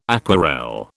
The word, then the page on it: aquarelle: British English